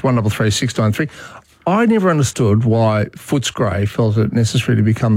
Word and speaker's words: Footscray: I like Footscray as a name. – Yeah. No, pronounce it properly. It's Footscray. – Footscray.